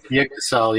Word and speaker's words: Yggdrasil: How do I say that? Yggdrasil? – Yggdrasil, yeah.